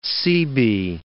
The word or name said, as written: Seabee